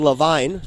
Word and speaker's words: Levine: Keep wanting to say Levine. It's spelled like Zach Levine. But it's Levine.